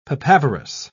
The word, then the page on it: papaverous: British English